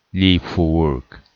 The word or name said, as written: leave for work